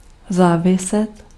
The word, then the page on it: dépendre: France; /de.pɑ̃dʁ/